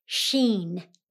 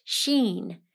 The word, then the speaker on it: Shin: The next stanza is called Shin. It looks like sin or shin, but it's pronounced sheen.